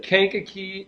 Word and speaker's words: Kankakee: Kankakee, Illinois.